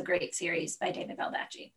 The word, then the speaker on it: Baldacci: Is it David Bald? I can't remember, Baldacci? – I don't know, how do you say it? Yeah, but no, it's Baldacci. – Baldacci.